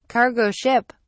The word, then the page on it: cargo ship: British English